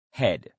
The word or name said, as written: head